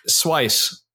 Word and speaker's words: Seuss: Yeah, Dr. Seuss. – I recently found out it's pronounced Dr. Zeiss. I'm still probably saying it wrong.